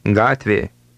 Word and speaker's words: Gatvė: Gatvė. – Note that Lithuanian G is always pronounced as in the English word go.